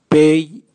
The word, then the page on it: bei: Raga